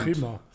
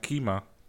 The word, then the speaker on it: Chima: Chima. So wie das Land Chima.